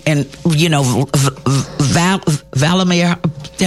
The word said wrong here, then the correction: Vladimir: help me pronounce his name. Vladimir Putin was there. – Vladimir Putin. Right.